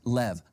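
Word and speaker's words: lev (לב): Now, heart in Hebrew is the word lev. It's pronounced lev, lamed bet. Now, I know I said bet, but in Hebrew, B can be V or B.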